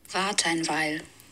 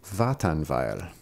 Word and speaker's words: Wartenweil: So here's how you pronounce that in German. – Wartenweil. – Wartenweil. – Wartenweil. Wait a while. – So I think it's literally wait a while.